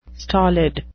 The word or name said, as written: stolid